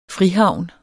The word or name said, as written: frihavn